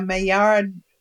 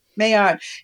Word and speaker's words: Maillard: So you have the rye, the Maillard. I'm not sure I'm pronouncing that right, but no, you got it. – Maillard.